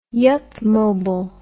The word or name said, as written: yupmobile